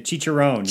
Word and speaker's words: chicharrón: We also really loved the, and I don't know how, if I'm going to say it right, chicharrón? – The chicharrón, yeah.